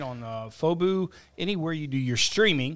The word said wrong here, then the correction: Fubo: Did I say it wrong? – FUBO.